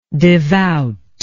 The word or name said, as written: devoutness